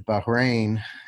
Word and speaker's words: Bahrain: Bahrain, if I said that correctly. Yeah.